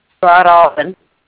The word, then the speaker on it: Broadalbin: I have billing address down as 597 County Highway 110, Broadalbin. Am I saying that right? – Broadalbin.